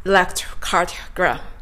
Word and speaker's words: electrocardiogram: では、ここまででですね、まず、 electrocardiogram と 3 つ山を書いてこの単語を発音するということを理解していただけたかと思います。